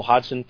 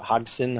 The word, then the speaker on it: Hodgson: I've always been more of a Joel Hodgson fan, or Hodgson, how I pronounce his name,